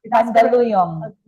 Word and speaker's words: Mandaluyong: Mandaluyong.